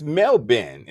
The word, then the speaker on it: Melbourne: Yeah, wait, and also it's Melbourne is how you say it.